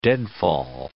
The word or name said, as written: deadfall